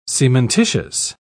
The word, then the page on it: cementitious: British English